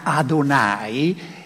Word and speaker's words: Adonai: pronunciar este nombre que en, hebreo, suena Adonai, es algo muy, muy, muy eh, sagrado.